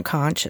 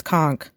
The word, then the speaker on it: conch: That's the right way to say it, right? It's not conch, it's conch.